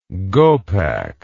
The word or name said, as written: gopak